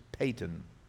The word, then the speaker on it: Paton: It's spelled P-A-T-O-N, but it's pronounced Payton.